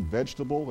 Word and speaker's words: vegetable: You ever look at that word vegetable? It has an E after the G before the T. Some people say it like this, vegetable. I kind of skip that.